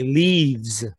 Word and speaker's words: Leafs: I like how you say it the right way. Leafs, boys.